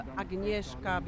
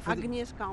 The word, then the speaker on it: Agnieszka: C'est Agnieszka en français, mais Agnieszka en polonais. – Faut prononcer le G. – Oui, oui, oui. – Agnieszka. – Agnieszka, je suis...